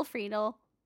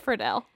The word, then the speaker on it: Friedle: Kim Possible's Will Friedle, which I know is not how you say his name, but it's just whatever. – It's Friedel.